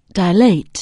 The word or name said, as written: dilate